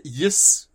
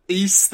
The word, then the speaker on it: Ys: Is it Yis? Weiss? I don't know. YS. East. There you go. – East. – See, I knew you were going to say it wrong. – That's how I have heard it before.